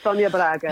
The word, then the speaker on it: Sonia Braga: Is that how her name is pronounced? – Correct, Sonia Braga.